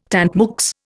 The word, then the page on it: tant mieux: Indian